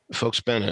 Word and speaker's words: Volksbühne: Volksbühne is the way it's actually pronounced.